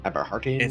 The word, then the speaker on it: Eberhardt: Eberhardt. – His first name is T-H-O-M. Tom Eberhardt, E-B-E-R-H-A-R-D-T.